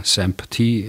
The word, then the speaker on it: SAMP-T: Also Abkürzung SAMP-T, SAMP-T im Englischen ausgesprochen.